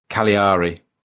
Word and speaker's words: Cagliari: Uh, Cagliari, I can't even pronounce the name anymore. – Cagliari. – Cagliari. There you go.